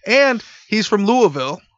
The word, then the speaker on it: Louisville: We do say Louisville because we know people. My brother used to be there, so I know you have to say it like Big Pun says Little Italy. Louisville. – Yeah. Do not say Louisville. Do not say Louisville. It is 100% Louisville.